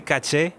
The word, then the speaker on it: caché: Se dice caché igual que en francés. – ¿Y cómo se escribe? – C-A-C-E.